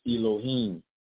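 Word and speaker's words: Elohim (אלהים): The word for God in that verse is a Hebrew word pronounced as Elohim. And to spell it as E, L, O, H, I, and an M.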